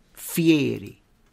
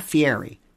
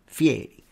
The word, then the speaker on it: Fieri: Notice how he said that, Fieri. Even he said it himself like that, Fieri. Which I like that. People pronounce it like that, Fieri. Not Fieri, Fieri.